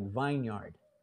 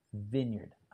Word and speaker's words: vineyard: See, if you read this here, the word looks like vineyard, and because it's full of grapevines, but it's pronounced vineyard.